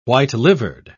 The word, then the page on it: white-livered: British English